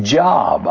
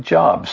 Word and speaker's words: Job: Some pronounce it Job. I have heard people say Job.